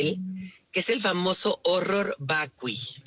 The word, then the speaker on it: horror vacui: Horror vacui. – Te voy a decir, Kate, a ver. Ajá, horror vacui. El horror vacui, o sea, pero se pronuncia horror porque está en latín.